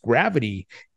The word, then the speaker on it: GRVT: Uh, GRVT, pronounced gravity, is a crypto project building a hybrid exchange, raised $7.1 million in pre seed and seed funding.